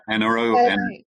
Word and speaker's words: Enero: Am I pronouncing that right? Enero? – Yes.